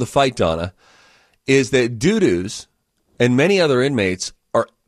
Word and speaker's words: Dudus: It's D-U-D-U-S. We get to choose how we pronounce it. – That's Dudus.